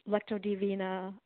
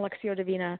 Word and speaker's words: Lectio Divina: So it's like the whole contemplative Lectio Divina. I said that wrong. Lectio Divina.